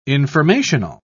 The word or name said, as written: informational